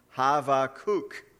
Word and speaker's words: Habakkuk: And so I looked into it a little bit, and in the Hebrew, if you were getting an actual perfect uh, pronunciation, the B actually sounds like a V. Did you know that? It's actually Havakuk.